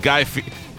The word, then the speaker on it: Guy Fieri: There's a guy named Eddie, and you got to pay a fee. There's a fee, Eddie, and that covers it.